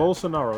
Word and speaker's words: Bolsonaro: Bolsonaro. That's how you say his name.